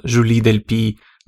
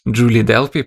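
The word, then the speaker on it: Julie Delpy: Э, мне буквально писали люди, потому что я называл Жули Дель Пи Джули Делпи, потому что это американское произношение.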